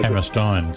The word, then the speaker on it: Hammerstein: And the Americans say Steen. Actually, it's E-I in German. It's pronounced I-N. – So Hammerstein.